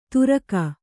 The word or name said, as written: ತುರಕ